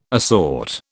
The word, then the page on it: assort: British English